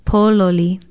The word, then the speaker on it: pololi: It is spelled P-O-L-O-L-I, and the first syllable is stressed, pololi.